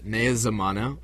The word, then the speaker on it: Naya Zamana: So the album is titled Naya Zamana, Welcome to the New Age. And can you spell that for us? – Yes. N-A-Y-A. And Zamana is Zamana.